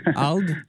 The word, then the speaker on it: ALD: Et tu as aussi été membre de ALDE, je pense que c'est comme ça qu'on le prononce. Et tu... – ah, c'est ALD. – ALD? – C'est ALD, c'est le nom de famille de ma femme, Sabrina ALD.